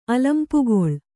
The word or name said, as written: ಅಲಂಪುಗೊಳ್